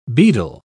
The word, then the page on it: bedell: British English